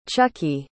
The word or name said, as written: chuckie